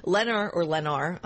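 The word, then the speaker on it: Lennar: You did have a, or I've been told it's pronounced Lenar. – Yeah, I think it's Lenar. – I've heard it both ways, but let's go with Lenar. – Yeah. Okay. – L-E-N is your ticker, up 1.2%.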